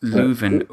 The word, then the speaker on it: Leuven: Leuven, I think, is how it's pronounced. – Leuven.